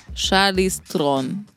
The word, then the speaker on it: Charlize Theron: Őt ejtik úgy is az ő nevét, hogy Charlize Theron, és a helyes pedig Charlize Tron.